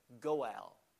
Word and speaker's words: Goel: The Hebrew word is pronounced as that, Goel, a kinsman redeemer.